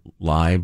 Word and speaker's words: Lai: Remember Jimmy Lai or Lie? I didn't remember how to pronounce it when we were talking about it last time. – I believe it's Lie, but...